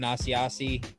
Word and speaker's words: Asiasi: Devin Asiasi.